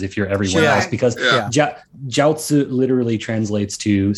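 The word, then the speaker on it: jiaozi (饺子): And say jiaozi, because those are my favorites. Except, oh my gosh, how do you pronounce it? I'm terrible. – Oh, no. I went with the Chinese pronunciation.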